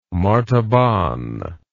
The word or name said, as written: martaban